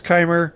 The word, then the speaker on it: Chimer: I had a feeling it was Chimer. Confirmed. That's how you say it.